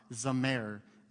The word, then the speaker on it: zamar: The third word we looked at last week, it's spelled Z-A-M-A-R, Zamer, Zamer, found in Psalm 144:9.